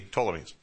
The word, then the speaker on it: Ptolemies: In Egypt, down here, you had the Ptolemies, all right, silent P, Ptolemies.